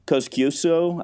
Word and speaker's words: Kosciuszko: And Kosciuszko, I'm probably not pronouncing that right, in Australia. Do I have that right? – Yeah, you've got it all. You got them all.